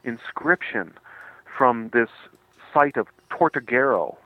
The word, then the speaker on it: Tortuguero: Tortuguero, yes.